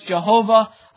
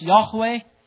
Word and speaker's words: Yahweh: This is the covenant name of God, sometimes pronounced Jehovah, I believe more accurately pronounced Yahweh.